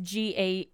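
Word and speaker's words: Jägers: Is it like almost spelt like Jägermeister? – Yeah, it's Jaegers.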